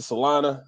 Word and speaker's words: Salina: My buddies are in Salinas, Kansas. – Salina. Yep. – Oh, is that Salina. How you say it? – Salina. Yeah, Salina.